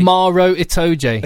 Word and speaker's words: Itoje: But I do think Cruis and Itoje now. – Itoje, it's Maro Itoje. – Itoje, yeah. it is. That's how he pronounces his own name.